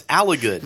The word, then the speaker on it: Alligood: Alligood. no, it's Alligood. No, like, it's it's it's a short I. No, it's like uh, like alligator.